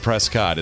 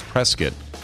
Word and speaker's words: Prescott: It's not Prescott. It's Prescott.